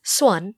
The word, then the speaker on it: Xuân: And then I think when we talked about our names, I like my Vietnamese name, which is Xuân. – Yes. – And it's basically pronounced... the number one with an S sound in front of it.